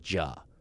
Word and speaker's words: Juh: The most classic way it's written is J-U-H. You would pronounce it Ja. Another book will write it as W-H-O-A.